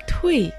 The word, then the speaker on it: 退: Слово «туй» произносится четвертым тоном, означает «отпустить», «отойди назад».